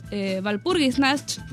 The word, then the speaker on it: Walpurgisnacht: Este tema es de Rufus Zufal, estuve practicando para pronunciarlo, es Walpurgisnacht, en alemán.